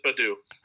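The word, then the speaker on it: Baddoo: Akil Badudu's. – I think it's just Badu. – Badu. Yeah, it's Badu, not Badudu's. But yeah. – Well, he will be referred to as Badudu from now on.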